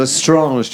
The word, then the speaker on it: Lestrange: Lestrange, do you hear that? The French pronunciation.